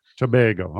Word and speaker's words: Tobago: Tobago.